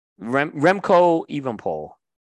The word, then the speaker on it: Remco Evenepoel: Renko Everpool. I'm going to butcher his name. I know. Remco Evenepoel.